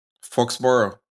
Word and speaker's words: Foxborough: Cambian su residencia a Foxborough, ¿Cómo lo pronuncias ese? Foxborough. ¿Foxborough? Foxborough.